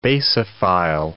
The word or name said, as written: basophile